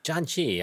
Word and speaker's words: janchi: John Chi. I was just explaining this to somebody else recently. It is not spelled like the name John and Chi. It is J-A-N-C-H-I.